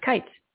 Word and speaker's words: Kites: Kites? Did I say it right? Knights. Kites. – Kites, yeah. Like knights. – Kites like knights.